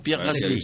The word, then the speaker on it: Pierre Gasly: Pierre Gasly.